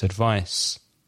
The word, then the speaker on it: advice: The difference in pronunciation with advice with a C, it's without vibration. It's without vibration in the throat. So it's advice. Advice. The verb advise has an S, but it sounds like a Z, una theta.